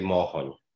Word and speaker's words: Mochon: Well, I accept all the versions of my last name pronunciations, but my dad would say Mochon.